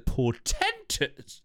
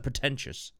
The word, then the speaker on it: portentous: To avoid the portentous. Or is that pretentious? Portentous. P-O-R-T-E-N-T-O-U-S. Portentous. There's no I.